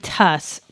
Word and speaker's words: Tas: It's Ben and Tuss. T-A-S is how it's spelled, but I think it's pronounced Tuss.